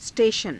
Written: s_teshana

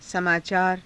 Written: samaacaara

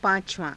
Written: paa~cavaa~

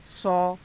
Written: sau

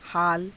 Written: haala

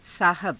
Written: saahaba

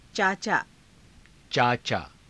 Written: caacaa

